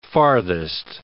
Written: farthest

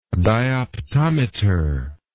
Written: dioptometer

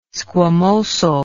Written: squamosal

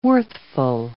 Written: worthful

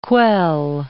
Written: quell

